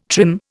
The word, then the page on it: trim: Indian